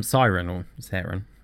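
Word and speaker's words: Seren: Siwelo Sieran. Siren. It's S-E-R-E-N.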